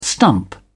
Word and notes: stump: British English

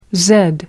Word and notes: zed: British English

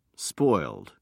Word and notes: spoiled: British English

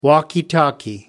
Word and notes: walkie-talkie: American English

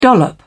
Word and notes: dollop: British English

